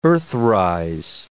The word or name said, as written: earthrise